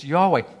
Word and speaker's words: Yahweh: is literally the word Yahweh or Jehovah or various other ways have been used for pronouncing it, but it's Yahweh.